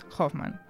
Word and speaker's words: Hoffmann: Doncs sí, es basen, tant com el seu nom indica, en els contes del poeta alemany Ernst Theodor Amadeus Hoffmann. Doncs aquest... Això sí, això sí que està ben pronunciat.